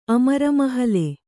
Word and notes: ಅಮರಮಹಲೆ: Kannada

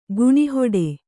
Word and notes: ಗುಣಿಹೊಡೆ: Kannada

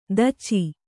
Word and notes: ದೋಚು: Kannada

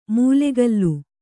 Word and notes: ಮೂಲೆಗಲ್ಲು: mūlegallu

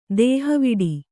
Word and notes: ದೇಹವಿಡು: Kannada

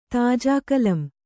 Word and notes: ತಾಜಾಕಲಂ: Kannada

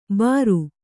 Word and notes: ಬರು: Kannada

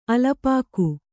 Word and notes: ಅಲ್ಪಾಕ: alpāka

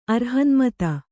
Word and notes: ಅರ್ಹನ್ಮತ: Kannada

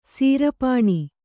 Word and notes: ಸೀರ್ಪನಿ: Kannada

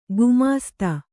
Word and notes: ಗುಮ್ಮಸ್ತ: gummasta

